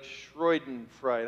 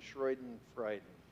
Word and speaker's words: Schadenfreude: We like Schadenfreude. How do you say that word? Schadenfreude.